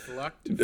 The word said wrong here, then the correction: fluked: Uh, Flucked is not the right way to pronounce that.